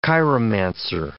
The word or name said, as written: chiromancer